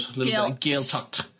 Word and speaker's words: Gaeltacht: It is part of a Gaeltacht. Did I pronounce that right? – Yeah, in a good American pronunciation. – All right, okay. Gaeltacht. We'll take it. – Put more kind of spit into it. Gaeltacht.